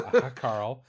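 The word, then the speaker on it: hákarl: It's called Hackarl. – Okay. – It is a... – A hot Carl. – It's from... No, not a hot Carl. A Hackarl. It's from Reykjavik or Iceland.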